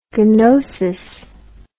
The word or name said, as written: ganosis